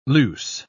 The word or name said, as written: luce